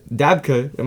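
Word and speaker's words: dabke: So they actually did a Dabka. Am I saying that right? – Yeah.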